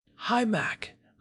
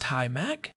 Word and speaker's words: HIMAC: HIMAC. I assume that is pronounced HIMAC. – That is the consensus.